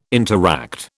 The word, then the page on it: interact: British English